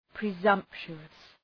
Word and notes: presumptuous: prı’zʌmptʃu:əs

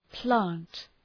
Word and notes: plant: plænt

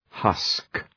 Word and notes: husk: {hʌsk}

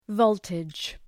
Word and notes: voltage: ‘vəʋltıdʒ

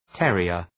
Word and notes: terrier: ‘terıər

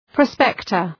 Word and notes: prospector: ‘prɒspektər

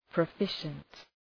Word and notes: proficient: prə'fıʃənt

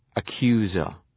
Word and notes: accuser: ə’kju:zər

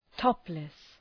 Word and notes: topless: ‘tɒplıs

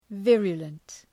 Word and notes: virulent: ‘vırjələnt

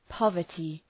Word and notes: poverty: ‘pɒvərtı

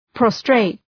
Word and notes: prostrate: ‘prɒstreıt

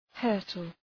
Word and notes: hurtle: ‘hɜ:rtəl

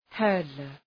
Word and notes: hurdler: ‘hɜ:rdlər

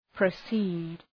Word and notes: proceed: prə'si:d

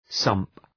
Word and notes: sump: sʌmp

